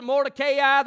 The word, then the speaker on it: Mordecai: Understand, if you want to pronounce it Mordecai, that's probably the proper pronunciation.